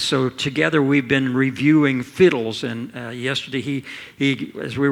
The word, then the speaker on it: FHIDLS: It contains, if you've been around here very long, you've heard the term fiddles, F-H-I-D-L-S. Sounds like fiddles, but it's spelled F-H-I-D-L-S.